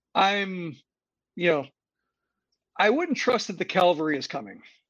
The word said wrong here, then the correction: cavalry: Yeah. Cavalry. Sorry. I mispronounced it.